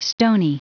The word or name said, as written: stoney